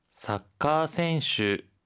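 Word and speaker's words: 사카센슈 (サッカー選手): 사카센슈 사카센슈 사카센슈라고 합니다. 앞서서 작가가 사카였잖아요. 그런데 축구는요. 사카 카를 장음으로 발음하면 축구가 돼요.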